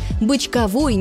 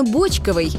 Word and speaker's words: бочковый: Бочковой – неправильно. Правильно – бочковый. Бочковый квас, бочковые огурцы, бочковая селедка.